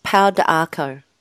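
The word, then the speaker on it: pau d'arco: It's pronounced Pada Arco. – Pada Arco, thank you.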